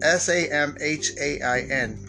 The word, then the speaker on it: Samhain: Halloween's roots date back to the ancient festival of Samhain, which is I don't understand. It's spelled S A M H A I N, but it's pronounced Samhain, like S O W I N.